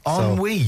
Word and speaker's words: ennui: Ennui, anyway, also played by a French person, by the way. – Wait, that's interesting, isn't it? Mm-hmm. That's the correct pronunciation. – Well, it's a French word.